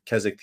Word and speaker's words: Keswick: and it's spelled K-E-S-W-I-C-K, but it's pronounced Keswick theology.